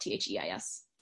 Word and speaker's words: Theis: So, and my last name is spelled Theis.